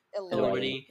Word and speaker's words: Elordi: There's there's a clip of him and Jacob Elordi. Is that how you say it? – Elordi.